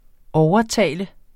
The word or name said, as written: OVERTALTES